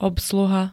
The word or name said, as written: obsluha